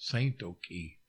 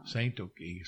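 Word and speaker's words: Tsatoke: And he said, "Saint Oki." That's how you say it in Kiowa. – Ah. – Saint Oki.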